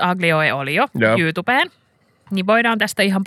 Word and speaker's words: aglio e olio: How do you say it? Aglio, meaning garlic in Italian, e olio. Aglio e olio.